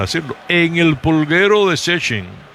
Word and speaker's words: Szczecin: Se pronuncia sencillito así, Szczecin, pero cuando usted la ve escrita, son un montón de consonantes, una pegada detrás de la otra, que usted no se puede imaginar que Szczecin es una ciudad fronteriza con Alemania.